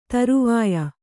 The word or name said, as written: ಧ್ರೌವ್ಯ